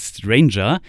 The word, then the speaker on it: strainjr: Ich unterhalte mich jetzt mit der Band Stranger, aber die schreibt man nicht so wie das englische Wort Stranger, also so etwas wie komischer oder verrückter, sondern wie Strain, das englische Wort für Belastung und JR für Junior.